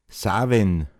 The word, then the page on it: åosauma: Pinzgauer Mundart